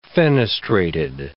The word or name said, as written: fenestrated